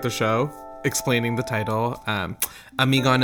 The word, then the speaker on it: Amigon: Amigon is a combination of the words, the Urdu word for mom, which is Ami, and the Greek tragedy Antigone, Amigon. But people say Amigani, am I gone?